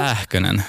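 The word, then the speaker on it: Pääkkönen: Uttalar jag namnet rätt? – Pähkönen. – Pähkönen. – Mm. Ja. Pähkönen. – Pähkönen. – Mm. Perfekt.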